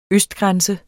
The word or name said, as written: østgrænse